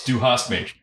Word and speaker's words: du hast mich: No, it's du hast mich.